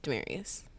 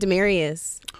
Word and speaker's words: Damaris: Your name is spelled Damaris. – Yes. – But it's Damaris? – Yes, it's pronounced Damaris. – Somebody forgot a letter. – Yeah, my mom named me. So she pronounced it Demarius.